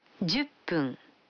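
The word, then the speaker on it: Дзюппун (十分): Вы заметили, что когда фун добавляется к 10, дю, то произносится пун. – Послушайте и повторите каждую фразу. 5 минут. – Гофун. – 10 минут. – Дзюппун.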